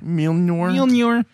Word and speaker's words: Mjolnir: Mjolnir.